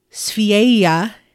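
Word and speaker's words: Sverige: I might have pronounced that wrong, but it's spelled N-O-R-G-E. Österreich is Austria. – Okay. – Suomi is Finland. Oh. Yeah. Sveia is Sweden. So that's S-V-E-R-I-G-E.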